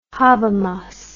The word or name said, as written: Habermas